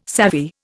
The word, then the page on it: savvy: Indian